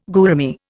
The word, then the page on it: gourami: Indian